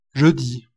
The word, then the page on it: jeudi: France